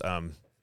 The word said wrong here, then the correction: Reuters: Reuters. – Reuters. Thank you.